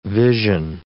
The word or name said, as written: vision